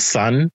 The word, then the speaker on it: Sun: So what is this one here, the Soon album? – Okay, so first of all, it's called Sun, like the sun, but it's spelled Sun. Sun. It's Sun. Sun